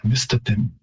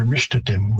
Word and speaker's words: Mistatim: I might, and I have been saying, certain pronunciations of Cree names, like Mistatim, but again, you might hear Mishtatim with Morbish instead of an S, and that wouldn't necessarily be wrong, depending on who's pronouncing it, what region they're from, and there are certainly dialect differences there as well.